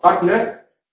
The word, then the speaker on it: Wagner: La W tiene dos pronunciaciones y se debe pronunciar como la B en las palabras de origen alemán, diremos Richard Wagner, pero como W en las palabras de origen anglosajón, no como decía mi padre, Washington.